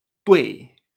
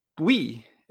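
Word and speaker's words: duì: Y esto se pronuncia tui. Tui. Nunca tui. Esto no existe en chino.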